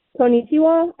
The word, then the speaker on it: Konnichiwa: Konnichiwa. I think that's it. I'm not sure. – No, that's right. It's K-O-N-N-I-C-H-I-W-A in English.